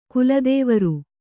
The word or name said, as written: ಕುಲದೇವರು